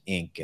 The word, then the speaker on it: Inque: I have to give them kudos for the name Ink. It's spelled I-N-Q-U-E and pronounced Ink.